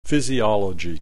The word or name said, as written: physiology